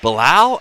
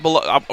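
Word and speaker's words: Belal Muhammad: Bilal, I think is how you're supposed to say it. Not Bilal. Look, I met him when he was on the regional stage. Like, not Bilal.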